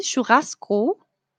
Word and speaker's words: churrasco: Ah, Mr. Javier me ayuda con la pronunciación del portugués de Brasil, churrasco. Churrasco. Creo que lo dije bien. No sé. lo intenté, Mr. Javier, tú me dices. Yo siempre intento lo mejor. churrasco, churrasco. Ok, muy bien. Dice Mr. Javier, sí, perfecto. Gracias por la ayuda con la pronunciación.